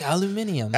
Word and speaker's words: aluminium: They're like, will you take this aluminum foil? And he's like, aluminium. – Aluminium. Yeah, I heard you're British there in that pronunciation of aluminum, bud.